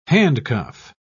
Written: handcuff

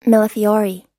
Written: millefiori

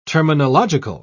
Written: terminological

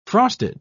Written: frosted

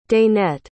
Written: danette

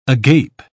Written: agape

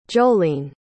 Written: jollene

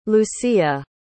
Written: lucia

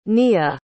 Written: nia

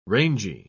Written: rangy

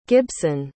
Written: gibson

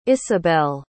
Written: Ysabel

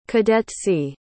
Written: cadetcy

